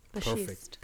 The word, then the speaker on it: Bashist: Bashist.